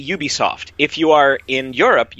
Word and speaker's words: Ubisoft: So it's U, like Y-O-U, Ubisoft. – Not Ubisoft. – Not Ubisoft.